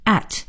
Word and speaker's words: at: 那么这个 A 圈应该怎么读呢？有的同学读圈 A 花 A，这都不对。在英文里呢，应该是读成 at，就是我们新学的这个 at 这个字。